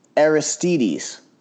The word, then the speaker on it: Aristides: And JD, I think his first name is pronounced Aristides. That's it.